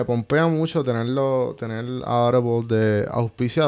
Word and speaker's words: Audible: Audible se escribe o se dice en español, un disparate. Se dice Audible, gente. Audible. Lo ahora con el inglés de este.